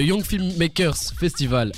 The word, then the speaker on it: Young Filmmakers Festival: Alors, le Young Filmmakers Festival, avec toutes les syllabes évidemment.